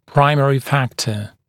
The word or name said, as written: primary factor